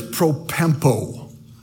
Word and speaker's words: propempo: The word is propempo. That's how you'd pronounce it. Propempo.